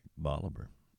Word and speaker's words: Bolivar: That's right, Bolivar. Yeah, it's the place you always wanted to be. Should be called Bolivar, or pronounced Bolivar, but because it was named after Simon Bolivar, Bolivar, a liberator in much of the Americas, and widely, approved of as the republics moved on and colonialism faded.